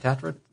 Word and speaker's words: Tatra: Am I saying that right? Tatra? Yeah. Nano.